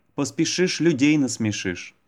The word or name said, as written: поспешишь людей насмешишь